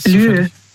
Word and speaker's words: lieu: Lieu. – Lü? – Also Lü, das schreibt man, also L-I-E und dann U. Le Lü.